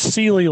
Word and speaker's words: Sieli: It's Sieli like the mattress.